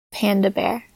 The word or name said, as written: panda bear